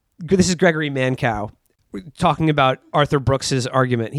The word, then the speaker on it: Mankiw: And then I found a review of it in the New York Times by the conservative economist Gregory Mankiw, Gregory Mankow is actually how you pronounce that.